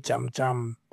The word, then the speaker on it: Jam Jam: Or So my name is Kanyisa Babini Jam Jam. So my surname is spelled J-A-M, J-A-M. – And that's where it comes from. – So, yeah. So you, in Xhosa, you pronounce it Jam Jam.